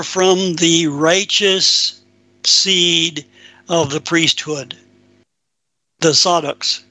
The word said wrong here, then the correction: Zadok: if I'm pronouncing it right. – Zadok, there we are.